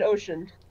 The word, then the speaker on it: Ocean: You see Sierran and Osen. – Like, totally mangled pronunciation, but sure, that's good too. – Yeah, that's as far as I can pronounce it. – Yeah, it's Kieran and Ocean.